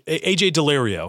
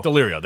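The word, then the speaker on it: Daulerio: Yeah, I forgot how to pronounce it, but yeah. – AJ Delirio. – Delirio, that's it, right.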